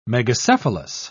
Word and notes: megacephalous: British English

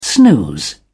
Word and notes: snooze: British English

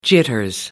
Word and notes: jitters: American English

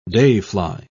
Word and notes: dayfly: British English

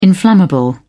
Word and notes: inflammable: British English

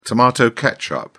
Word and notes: tomato ketchup: British English

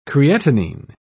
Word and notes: creatinine: British English

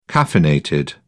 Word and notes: caffeinated: British English

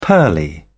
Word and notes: pearly: British English

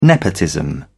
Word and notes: nepotism: British English

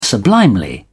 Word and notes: sublimely: British English

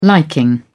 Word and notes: liking: British English

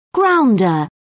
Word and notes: grounder: British English

